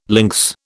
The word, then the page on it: lynx: British English